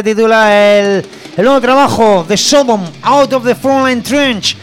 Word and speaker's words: Sodom: Así que ahí va Out of the Frontline Trench, que es lo nuevo de los alemanes Sodom.